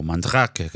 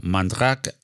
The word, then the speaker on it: Mandrake: Bueno, pues eh, entramos en materia directamente porque no podemos hablar de Gael sin por lo menos mencionar a Mandrake o Mandrake, que creo que se pronuncia algo más parecido en francés.